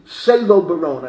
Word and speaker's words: Salo Baron: She studied under Sala Baron. Sala Baron was maybe one of the leading names in Jewish scholarship in the Salo Baron, I think you pronounce it. Salo Baron.